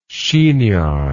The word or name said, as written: chignoned